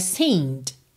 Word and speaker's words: seemed: como esse é que vem antes do D é mudo, eu teria seemed, seemed, terminando o som na letra D.